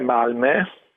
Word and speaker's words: Malmö: Beh, la pronuncia è Malmö, con quella la O con i due punti sopra, che a differenza che in tedesco non è da considerare come una dieresi, ma è proprio una vocale a sé stante a questa pronuncia, che è una E.